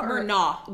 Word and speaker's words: Murnau: I said it correctly. Murnau? – Murnau. – With a G-H at the end? – No.